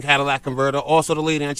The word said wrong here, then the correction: catalytic: I want to say it's catalytic. It's not Cadillac Converter.